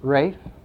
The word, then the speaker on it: Ralph: Robin and another servant named Ralph, the English pronounce it Rafe.